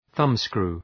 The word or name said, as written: thumbscrew